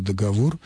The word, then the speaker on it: договор: Тем более, что даже современные словари не уравнивают эти варианты и категорически не рекомендуют произносить слово «договор» с ударением на первом слоге.